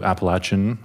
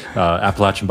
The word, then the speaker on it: Appalachian: ended up going to Appalachian, that's the proper pronunciation, Appalachian Bible College in West Virginia, and then came to Virginia in 2014.